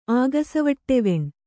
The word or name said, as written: ಆಗಸವಟ್ಟೆವೆಣ್